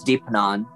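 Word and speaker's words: Deipnon: And I actually would love to hear about um, like any rituals, because I know I saw you talk about, I'm going to say it wrong, but Deapnan. – I believe it's Deepanan or, okay.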